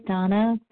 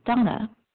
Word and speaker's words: Dana: This is Donna B., recovered compulsive overeater and bulimic, calling from Denver. My name is spelled D-A-N-A, although it's pronounced Donna.